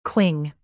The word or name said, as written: cling